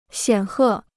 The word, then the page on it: 显赫: xiǎn hè